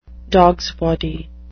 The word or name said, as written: dogsbody